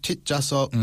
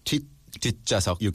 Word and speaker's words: 뒷좌석: 앞 좌석, 뒷좌석. – Mm-hmm. – Do you pronounce that 시옷? – 뒷좌석. – You kind of make a little clip there.